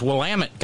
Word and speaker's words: Willamette: It's Willamette, goddammit.